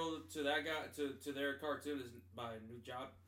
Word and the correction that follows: Nujabes: Nujab. I'm probably butchering the name mm-hmm. Nujabis Nujabes, mm-hmm.